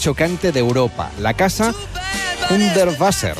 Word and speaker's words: Hundertwasser: Me lo han corregido antes, Hundertwasser. – Es Hundertwasser. Sí, sí, Víctor. – Víctor que sabe alemán, sí, inglés, italiano. – Sí, me ha dicho Hundertwasser.